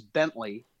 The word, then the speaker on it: Bentley: But there's nothing more irritating than, like, my last name is Bentley, and the clearly obvious looking way to spell it is Bentley, like the fancy car. – Right. – And I don't know how many times in the last 39 years someone has spelled it without the E at the end.